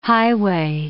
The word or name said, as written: highway